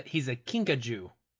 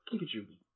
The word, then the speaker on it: kinkajou: No, he's a kinkajou. – Okay, kinkajou. Oh, kinkajou. – Kinkajou. – How do you pronounce it? Kinkajou. Okay. – It kind of rhymes with Pikachu.